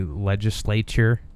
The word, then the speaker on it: legislature: That kind of reminds you ever hear people say legislature? Like that word drives me nuts. Like, yeah, that, like the T-U-R-E. – It's the sure. – You just gotta say it like it's C-H, right? – Yeah.